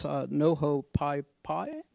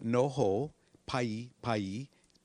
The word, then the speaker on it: Noho Pai Pai: So the Hawaiian language actually has the same pronunciation as Latin. So Noho Pai Pai, P-A-I, P-A-I.